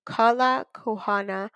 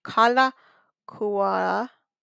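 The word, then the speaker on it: Kalākaua: Kalakuana. There's no N. Sorry, I'm trying to pronounce it correctly. Kalakuana Avenue, just opposite the Royal Hawaiian Hotel, to a grouping Kiwi trees.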